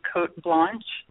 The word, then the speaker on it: Cote Blanche: Am, am I saying this properly? Cote Blanche. – Yes. Yes.